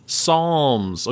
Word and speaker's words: Psalms: Psalms. – Okay, you're pointing to your palm. – My palm, because it kind of sounds like rhymes with palm. I was trying to help.